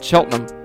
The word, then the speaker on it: Cheltenham: So I spell it Cheltenham, C-H-E-L-T-N-U-M. If you spell it like that, you can say it correctly. It's just like Tottenham. You know, it's not Tottenham. You just spell it T-O-T-N-U-M, Cheltenham.